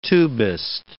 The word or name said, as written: tubist